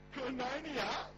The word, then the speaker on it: koinonia: This is really, I was used to say it like koinorrhea, and it sounded really bad. I was like, oh, I hope the church doesn't have a problem with koinorrhea now. That's a, so, koinonia, it's K-O-I-N-O with a little dash on top, N-I-A.